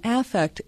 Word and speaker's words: affect: Affect theory is Affect. Yeah. Affect theory. Okay. – Yeah. And I always overpronounce the A, so it's clear that that we're talking about emotion.